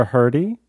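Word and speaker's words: Hrdy: Sarah, I'm going to pronounce this incorrectly, Hurdy. – I think, but Sarah Hurdy? Who's she? – Yes, Sarah Blaffer Hurdy.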